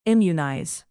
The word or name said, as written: immunize